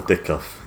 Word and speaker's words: Dickov: It's spelled a bit different, but it's still Paul Dickoff. He's just got a P in the middle of it as well.